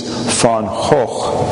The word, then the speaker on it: Van Gogh: First of all, so I could show off the Dutch pronunciation of his name, which is Van Gogh.